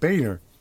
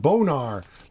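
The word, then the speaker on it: Boehner: House of Representatives, if you read his name, it also looks like his name could be Boner. But in fact, it's at least he pronounces it Boner. This guy could pronounce his name Bonar, like sonar or something, but no.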